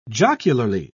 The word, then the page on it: jocularly: British English